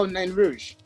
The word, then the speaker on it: Nain Rouge: But yeah, so many great releases from you, including, and correct me if I'm pronouncing it wrong, is it Nain Rouge or Nain Rouge? – Oh, Nain Rouge. Yeah.